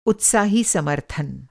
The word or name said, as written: उत्साही